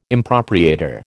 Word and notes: impropriator: American English

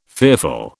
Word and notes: fearful: British English

